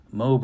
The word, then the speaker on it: MOBE: And there's a sign on that page that says, MOBE rhymes with lobe, like your earlobe.